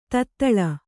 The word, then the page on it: ತೊದಳ್: todaḷ